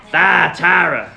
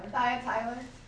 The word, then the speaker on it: Thyatira: Thyatira. – Thyatira. – Thyatira.